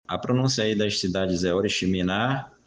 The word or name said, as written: oriximin